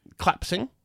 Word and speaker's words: collapsing: It's three syllables. – What's wrong with saying collapsing? – Because you make it two syllables. You rob it of a syllable. Where you are happy to charitably give February the R audibly, collapsing does not get the same treatment.